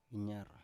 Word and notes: inyar: Biak

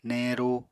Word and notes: neñru: Apatani

